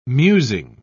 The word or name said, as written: musing